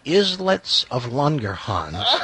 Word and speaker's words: Islets of Langerhans: Well, what's funny is, this Mr. Clark, my hillbilly Hicktown, Tennessee teacher, hadn't even pronounced it correctly. It's the Islets of Langerhans. and it's a part of your pancreas.